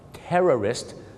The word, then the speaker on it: terrorist: ในการออกเสียงคือพยางค์สองพยางค์ Tourist นักท่องเที่ยวกับ Terrorist สามพยางค์ครับผมนะกลัวนะครับว่าคุณจะเป็นเหมือนลูกศิษย์ผมคนนึงครับฝรั่งถามว่า Why did you come to America?